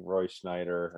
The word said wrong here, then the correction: Scheider: or Snyder or whatever we pronounce it. – Scheider. – It's not even, there's no N in it. Okay. Roy Scheider does a great job.